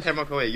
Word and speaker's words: Tecmo Koei: Sorry if I said that wrong. – Yes, Tecmo Koei, you're right.